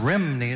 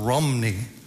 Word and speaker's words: Rhymney: It's not the Bells of Rhymney, it's the Bells of Rhymney. Spelled R-H-Y-M-N-E-Y, and R-H-Y in Welsh is rum, rah, rum.